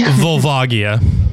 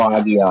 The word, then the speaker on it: Volvagia: How do I say that? Help me. – Volvagia. – Volvagia. Yeah, Volvagia, I think mm-hmm. how I've heard it.